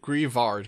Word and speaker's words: Grievard: I think it's Grievard because it's like graveyard.